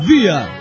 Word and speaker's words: Bier: Se pronuncia vía. – Vía. – ¿Por qué vía? Porque la R al final pronunciarse es una cosa rara entre la A y la R.